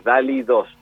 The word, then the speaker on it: DALL-E 2: DALL-E es con D-A-L-L, un guión en el medio, la letra E y el número 2. Así se pronuncia esto, es DALL-E 2.